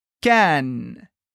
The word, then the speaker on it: Cannes: Cannes. Cuidado que em português a gente lê como se escreve, portanto, Cannes, como o Festival de Cannes. Mas em francês é sempre Cannes.